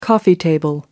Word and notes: coffee table: American English

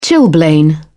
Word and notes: chilblain: British English

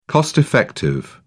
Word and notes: cost-effective: British English

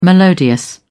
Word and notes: melodious: British English

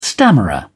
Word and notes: stammerer: British English

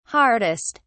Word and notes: hardest: British English

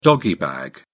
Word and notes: doggy bag: British English

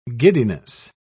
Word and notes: giddiness: British English

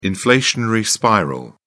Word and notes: inflationary spiral: British English

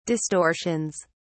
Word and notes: distortions: British English

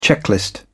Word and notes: checklist: British English

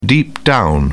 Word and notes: deep down: British English

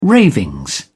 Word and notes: ravings: British English

